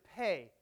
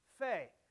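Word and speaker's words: pe (פ): And the Hebrew letter is pe, or pa. Sometimes it is pronounced fe. It does act like the P letter in our English alphabet with that P sound, though in certain situations it can have an F sound, just like our P letter when an H is added, right?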